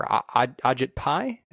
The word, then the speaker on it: Ajit Pai: Ajit Pai, is that how we're pronouncing it? – Okay.